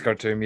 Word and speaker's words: Khartoum: Yeah, it's Khartoum.